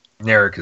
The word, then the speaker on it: Narek: And I think that this thing that's going on, would you say his name is Narek? – Narek is the Romulan.